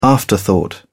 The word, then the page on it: afterthought: British English